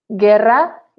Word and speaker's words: guerra: The U in this case is silent. Guerra, guitarra.